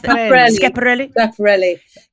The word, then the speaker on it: Schiaparelli: Schiaparelli.